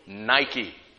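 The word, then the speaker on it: Nike (Νίκη): And this is the victory, the Greek word nikeo, from which the tennis shoe company got the logo Nike. Nike is how it's pronounced in the Greek.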